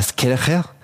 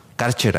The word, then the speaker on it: Kärcher: De repente una hidrolavadora como la Skircher, o bueno, pronunciado en buen alemán, la Skircher. Estas lavadoras que echan agua hacia presión. – Las Karcher para la raza. – Así es, las Karcher. Karcher aquí en México.